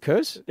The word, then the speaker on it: Kurz: Is it Kurz? – Yeah.